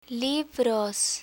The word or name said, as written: libros